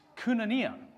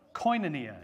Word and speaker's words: koinonia: Again, if you've been around in church a little while, then you may have heard it said that the Greek word for fellowship is koinonia, or koinonia, as some people might pronounce it.